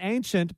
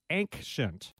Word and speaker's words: ancient: For some reason, early in life, I read that word and pronounced it not ancient, but ancient. Hard C, hard K sound.